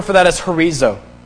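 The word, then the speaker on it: horizo (ὁρίζω): It's H-O-R-I-Z-O. The Greek word for horizo, the Greek word for declared is horizo, H-O-R-I-Z-O. You pronounce it H-O-R-I-D-Z-O, Z-O, horizo.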